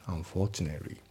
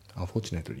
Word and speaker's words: unfortunately: であと発音ですけど、 unfortunately の t の部分がね抜けますね。 unfortunately、 unfortunately になって unfortunately。